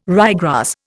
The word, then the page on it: ryegrass: Indian